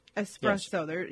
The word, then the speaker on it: espresso: So don't, no, and don't call it Espresso. That's not going to work either. you just have to pronounce it the way the Italians do, because it's an Italian word.